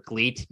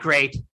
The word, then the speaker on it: Gleat: And like, he's full on doing stuff for Gleat. Not great. Yeah, it's spelled G-L-E-A-T. I know W, but like, it's, you know, L's and R's and mix. And it's like, I know, I fucking lived there for 10 years. Yeah, I know. But listen, they're going to spell it with an L.